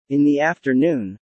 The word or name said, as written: in the afternoon